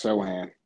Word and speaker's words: Sochan: If the Jazz are here and there is no Griffin, no Keegan Murray, I think they would do something like, is Sochan, is that how you say his name? – I think it's Sohan. I think Sohan. The C's silent.